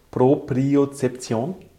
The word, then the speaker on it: Propriozeption: Ich hoffe, ich spreche es richtig aus. Propriozeption. – Ja. – Habe ich dich ausgesprochen? – Ja.